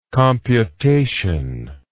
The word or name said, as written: computatively